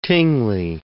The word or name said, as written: Tingley